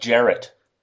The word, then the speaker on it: Jarrett: It's pronounced Jarrett.